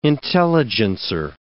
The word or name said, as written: intelligencer